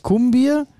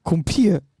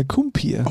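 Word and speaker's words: Kumpir: Ja, die deutsche Kartoffel sagt Kumbier und die richtige Sache. Gibt's denn dazu Lache? – Kumpier. Ja, wie spricht man es dann aus? – Ja, Kumpier.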